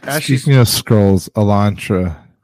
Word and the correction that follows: Elantris: Am I saying it right? – Yeah, Elantra. Yeah. What about her? – No, I'm saying the wrong. What's the name? – Says Sanderson. – Sanderson. – Oh, Elantris.